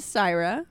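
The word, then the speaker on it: Sirah: My name is Syra.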